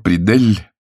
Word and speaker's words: Придель: Мистер Теодор Придель, ударение он ставил на последнем слоге, избрал своей специальностью подражание покойному президенту Рузвельту.